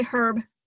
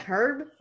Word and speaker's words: herb: How British people don't really have, like a silent H. Like, they say herb. – I know. Like, that makes me uncomfortable. Like, herb?